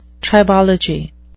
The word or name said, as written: tribology